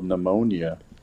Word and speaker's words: pneumonia: Very proud because in sixth grade, I was in a spelling bee and I spelled pneumonia incorrectly. – Oh, that P will get you so silent. – To go from sixth grade, the devastation of forgetting the silent P,